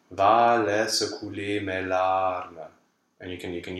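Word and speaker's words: couleur: We don't say the R at the end of couler. – No, we don't. So that's a silent R.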